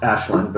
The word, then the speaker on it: Aisling: Pronounced Ashlyn, but spelled Aisling because why not? – Gaelic.